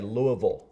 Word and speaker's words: Louisville: Or as the locals say, Louisville. – Louisville. – Louisville. You know the locals, and you know when you're not a local.